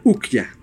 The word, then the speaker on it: Okja: Sí, y en Netflix hay una película que se llama Ukja. Se escribe O-K-J-A.